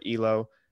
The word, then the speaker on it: Elo: 538 likes to use the ELO or ELO, whichever you prefer to pronounce it as,